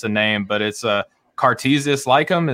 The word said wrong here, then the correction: Lyceum: Is that right? – Cartesius. – Am I close? – Lyceum. Yeah, you're somewhat close.